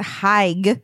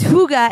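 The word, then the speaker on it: hygge: I want the huga, which is looks like it should be pronounced Haig, but it's huga, and it's this like essential it means like coziness, right?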